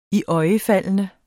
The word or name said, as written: iøjnefaldende